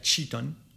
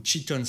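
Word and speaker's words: Tri Tôn: Ces zones-là, je pense notamment à Chavin, à Sok Chong et à Chiton. Alors ça s'écrit T-R-A plus loin V-I-N-H, S-O-C plus loin T-R-A-N-G, ou Chiton, ça c'est carrément un district dans une province T-R-I plus loin T-O-N.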